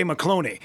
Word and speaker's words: McCloney: it's Fancy Ray McCloney. It rhymes with baloney.